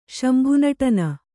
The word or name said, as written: ಶಂಭುನಟನ